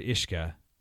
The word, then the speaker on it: uisce: So whiskey is the anglicization of the Gaelic word ishka, which is spelled U-I-S-C-E.